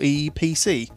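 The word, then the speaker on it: Eee PC: That's a capital E, small e, small e, PC. – By? – Is that Asus? – Asus. – Oh, Asus. – Now, this is actually pronounced the EPC.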